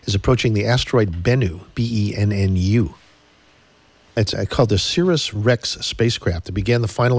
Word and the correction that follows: OSIRIS-REx: I think they pronounce that OSIRIS. – OSIRIS? Yeah. Cirrus? OSIRIS? Okay.